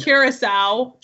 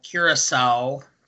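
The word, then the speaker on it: Curaçao: Aqua Velva is one shot of vodka, one shot of gin, one shot of blue curacao, and Curacao. – Curacao.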